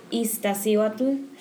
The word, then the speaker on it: Iztaccíhuatl: Well, first of all, it's called Iztaccíhuatl, which means La Mujer Dormida, which is the sleeping lady.